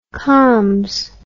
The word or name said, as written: combs